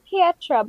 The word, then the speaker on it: Pietra: I think it's Pietra, but I could be wrong. – Pietra? – Yeah, it's spelled like Pietra. – P-I-E-T-R-A.